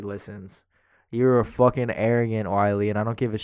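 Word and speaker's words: Wile: It's not pronounced Willie the Coyote, is it? Uh, Wiley, son.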